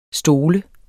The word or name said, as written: stol